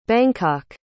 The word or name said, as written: bangkok